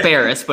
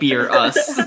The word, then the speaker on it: Beerus: Well, it's Beerus, but it's spelled like Beer Us. Beerus, yeah.